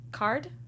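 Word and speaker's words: Karrde: K-A-R-R-D-E. I don't know how to pronounce that. Card? – Talon Card? – Oh, Talon Card.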